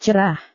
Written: cerah

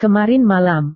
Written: kemarin malam

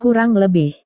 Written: kurang lebih

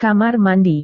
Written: kamar mandi

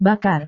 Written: bakar

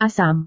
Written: asam

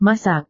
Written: masak

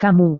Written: kamu

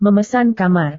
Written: memesan kamar

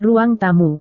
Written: ruang tamu

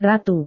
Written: ratu

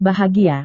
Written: bahagia

